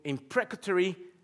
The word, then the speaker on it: imprecatory: If you want to know the interesting term that these prayers are called, they call imprecatory prayers.